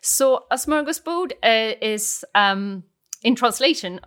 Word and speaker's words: smörgåsbord: Now, I think that some of our listeners may have heard of the word smörgåsbord. Have I said that correctly? – You said it perfectly, and I think it's one of the most famous words for, in Swedish.